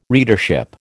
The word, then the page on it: readership: American English